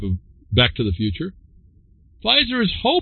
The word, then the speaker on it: Pfizer: Pfizer, silent P, Pfizer, begins trials to bring COVID inhibitor in pill form.